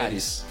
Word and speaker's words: Eares: É Eares mesmo? Fala o Eares. – Eares. Isso, Eares. Eares. É, se fosse puxar para o inglês, a gente mudaria a pronúncia, mas Sim. Em português, como nós somos brasileiros, pronto. – Eares, como está escrito.